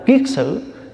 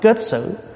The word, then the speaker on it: kiết sử: Cho nên gọi là phiền não là cái mà nó sai sử mình, gọi là sử, là cho nên gọi là kiết sử. Chữ kiết là cột, chữ sử là nó sai mình. Có khi mình đọc là kết sử.